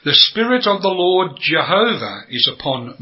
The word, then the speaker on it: Jehovah: Now, why have I said Yehovah? That is the Jewish pronunciation. We're not Jews, we're English. And the most commonly accepted form is Jehovah.